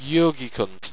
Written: jogicunt

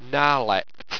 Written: narlekt

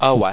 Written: owa

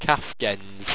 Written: kafgennz

